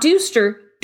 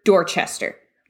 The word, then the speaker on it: Dorchester: You have Dorchester, pronounced not Dooster, Dorchester.